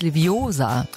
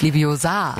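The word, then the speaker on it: Leviosa: Es heißt Liviosa, nicht Liviosa.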